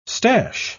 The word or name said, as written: stash